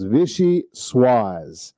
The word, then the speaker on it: Vichyssoise: Vichyssoise.